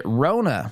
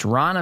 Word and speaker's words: Ronna: I was watching as the ABC News journalist, if you want to call her that, she introduced, as she put it, Rona McDaniel. It's Ronna McDaniel.